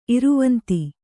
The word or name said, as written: ಇರವಂತಿ